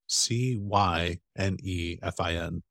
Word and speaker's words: Cynefin: done some more research in Cynefin, which is spelled C N E C N E C Y N E F I N, Cynefin. It's a Welsh word.